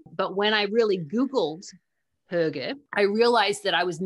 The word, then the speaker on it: hygge: And actually, how do you say, you know, when you can't have a hoog, you can have a hygge.